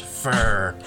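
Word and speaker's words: FHR: I'm going to call them FHRs, pronounced fur.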